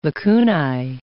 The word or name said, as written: lacunae